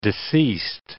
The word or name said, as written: deceased